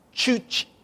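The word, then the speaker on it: Juche: They reject all teachings of God, but they have this tower of Chuche. C-H-U-C-H is one of the ways. That's how you pronounce it. Chuche.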